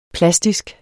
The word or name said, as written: plastisk